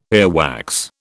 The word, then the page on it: earwax: British English